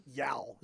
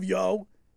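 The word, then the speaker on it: Yeo: But you know what? While looking at the two benches, Mike Yao. Is that how you say his name? – Yo. Yeah, it's a very Philly name.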